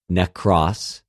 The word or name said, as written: νεκρος